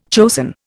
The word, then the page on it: chosen: Indian